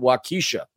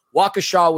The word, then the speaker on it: Waukesha: I pronounced it Waukesha. My bad. Waukesha, Wisconsin, a lovely city about 16.5 miles away from Milwaukee with a population of 72,000.